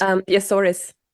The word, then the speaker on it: Azores: I don't know how to say this. – Azores. – Azores.